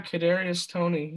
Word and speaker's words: Kadarius Toney: How are you with Kadarius Toney? – Okay. – Is that how you say his name? – Yeah, Kadarius Toney.